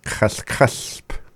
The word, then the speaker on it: Kaskasp: And if you want to know how to say the raspberry bush, you just add a sp at the end, which is LHP. Sp. Kaskasp.